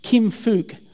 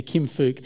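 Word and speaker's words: Kim Phúc: And the girl in that photo, the centre of that photo, Kim Phuc, you've got to be really careful how you pronounce Korean names, but I think that's the closest I get there, Kim Phuc.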